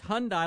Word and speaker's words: Hyundai: Okay, it's Hyundai. A lot of people say Hyundai.